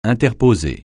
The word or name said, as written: interposer